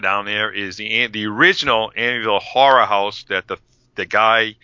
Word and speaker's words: Amityville: Jeez, I can't even say it if I say that right. – Amityville. – Thank you.